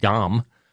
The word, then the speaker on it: Ngom: That's when jury selection in the aggravated vehicular homicide and other charges against Modu Dom, I guess is how you say his name. It's spelled N-G-O-M. The N is silent. Modu Dom.